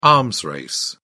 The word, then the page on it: arms race: British English